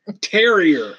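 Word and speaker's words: terroir: The mission to infuse terror into premium barrel-aged brews. I think I just fucking said that French fucking word. – Terrier is actually how you say it. – Oh, like the dog.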